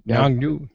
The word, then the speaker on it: Yongnuo: But there's a great brand out there called, I'm going to mispronounce it here, Yongnuo or whatever? – Yongnuo.